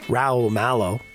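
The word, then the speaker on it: Raul Malo: Raoul Mallow. There you go. Say it correctly.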